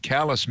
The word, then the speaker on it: Calais: Calais, Maine is spelled like the French city Calais, but it's pronounced Calais.